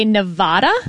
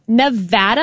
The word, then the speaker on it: Nevada: Because a lot of politicians would say Nevada. – Wrong. – And that is wrong. – It is Nevada. And I had to train myself to say Nevapple so I could pronounce the A right.